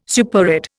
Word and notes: superadd: Indian

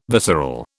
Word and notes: visceral: British English

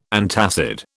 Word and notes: antacid: British English

